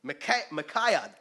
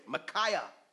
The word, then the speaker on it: Micaiah: Micaiah, there you go, Micaiah, the son of Imlah, by whom we may inquire of the Lord.